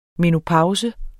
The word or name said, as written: menopause